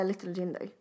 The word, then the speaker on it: Little Jinder: Little Jinder. Det uttalas på svenska.